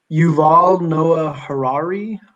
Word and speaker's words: Yuval Noah Harari: Yuval Noah Harari.